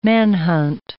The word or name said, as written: manhunt